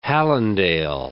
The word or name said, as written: Hallandale